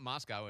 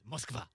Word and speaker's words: Moskva: It's not, it's not Moscow, it's Moskva.